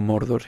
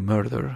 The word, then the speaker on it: Murder: Love and Murder, no Mordor, sino Murder, asesinato, es que quiero pronunciarlo también y me queda un poco ridículo, pero bueno.